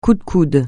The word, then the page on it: coup de coude: French